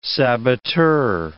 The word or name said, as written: saboteur